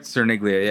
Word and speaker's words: Cerniglia: Now, am I pronouncing the last name right? Cerniglia? – Cerniglia, yeah. Okay. That's right.